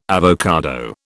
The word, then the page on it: avocado: British English